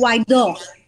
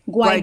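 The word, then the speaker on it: Guaidó: Is it Gaido or just Gato, the new it's guy? It's Guaido. Guaido. Guaido. – Okay. Well, I was pretty far off.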